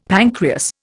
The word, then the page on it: pancreas: Indian